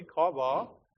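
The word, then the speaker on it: Chava: And then her name was Eve, and in Hebrew it's pronounced this way, Kava.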